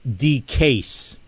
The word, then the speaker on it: DeCase: It's pronounced D-case, as in D-case of ammo.